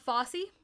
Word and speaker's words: Fosse: Bob Fosse, I believe that's how you say that. Yeah. It's F-O-S-S-E. – That's how I always pronounce it.